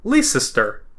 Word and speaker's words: Leicester: Because this city is pronounced L-E-I-C-E-S-T-E-R. – That's how it's spelled? – Yeah. Okay. I've only ever seen it written. So I've always said, like, Leicester. It's yeah. Leicester. – Just Leicester? – It's bonkers, yeah. Leicester.